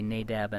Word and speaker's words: Nadab: It's something like Nadav and Avihu. We usually say Nadab and Abihu because we can't say it the way it's supposed to be pronounced, and that'll do.